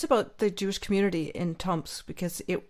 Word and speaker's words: Tomsk: Tomsk, but only 3 years.